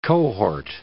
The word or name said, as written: cohort